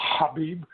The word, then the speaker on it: Khabib: I think Khabib, or as I love the way Proteus said it because he actually pronounced Khabib. It the right way. Khabib.